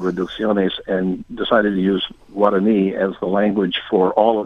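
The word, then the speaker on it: Guaraní: The pronunciation is Guarani? – Guarani, right. Okay.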